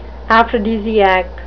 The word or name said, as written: aphrodisiac